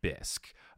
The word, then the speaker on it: bisque: And then I said, oh, just so you know, you pronounce it like it's with a K, like it's just said bisque.